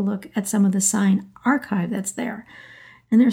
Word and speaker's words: Sayen: The photographer's name turns out to be properly pronounced Gertrude Sayn. The last name is spelled S-A-Y-E-N with an umlaut over the Y, and Sarah and I, during the episode, mistakenly were pronouncing it as Sayn. It's really Gertrude Sayn.